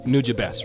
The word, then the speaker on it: Nujabes: Here's the thing, just getting a little more technical about Nujabes, right? That's how you pronounce it.